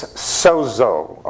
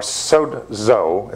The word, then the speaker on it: sozo: So let's look at the word saved as it's used in the Christian Bible, translated from the Greek The word saved is sozo, or sozo, it's pronounced, but it's S-O-Z-O, S-O-Z-O.